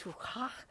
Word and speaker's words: ruach: Well, Spirit, the play on that word is ruach, which means breath or wind or spirit in Hebrew. Do you like how I said that? That's how you say it. That's Hebrew. Those of you who are taking Hebrew, you know, you have to learn to do that.